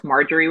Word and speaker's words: Marjorie: was constantly like, no, it's Marjorie with a J, Marjorie with a J, Marjorie with a J.